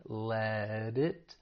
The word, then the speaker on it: let it: We're going to pronounce this as a flap T. That T comes between two vowel sounds, so it's going to sound like a flap T, which is more like a soft D. Let it. And we can take that consonant D sound and link it with the vowel sound in it.